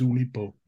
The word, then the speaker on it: Oulipo: It's well known within literary circles, and pardon my pronunciation, Oulipo. – Your pronunciation is absolutely correct. It is Oulipo.